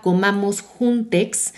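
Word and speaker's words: comamosjuntxs: Y lo que estamos haciendo es que a través de la cuenta de Instagram, @comamosjuntex, se escribe comamos y después juntos con X, comamos JUNTXS.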